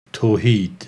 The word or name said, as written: Tohied